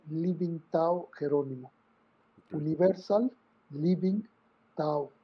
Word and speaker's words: Tao: Así te, con T de Tomás, porque también lo van a encontrar en inglés con D de Daniel Tao. – Mm. – Pero eh, sí, normalmente saca lo pronunciamos así con T y,